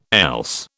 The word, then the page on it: else: British English